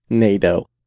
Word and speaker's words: Nado: Nado. Jonathan Nado. – Yeah, like Play-Doh.